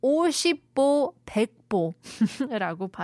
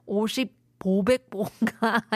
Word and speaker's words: 오십보 백보: But apparently I'm not wise enough to pronounce it correctly. 50보 100보라고 발음해야 되는데 50 500보가 아니라고 합니다. Sorry.